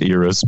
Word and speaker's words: Eras: Is that how it's pronounced? – The ear at? No, it's Eras, but it's okay.